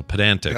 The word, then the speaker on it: pedantic: So pedantic. Yeah. Not pedant. – Pedantic. Pedantic. You obviously put the emphasis on the middle syllable, but we're doing exactly what he doesn't want us to do.